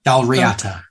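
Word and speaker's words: Dál Riata: Is that Dal Raita? – Dal Riata. Riata. – Riata. Dal Riata.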